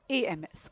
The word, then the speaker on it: alms: ALMS, pronounced as AMS. Okay, alms."